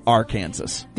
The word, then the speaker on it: Arkansas: It's spelled the exact same way, and they pronounce it Arkansas.